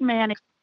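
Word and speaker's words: Manteo: It is pronounced Manio. We do not pronounce the T, because in the South, we don't have time for all those letters when we talk.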